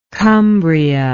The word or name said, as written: cumbrian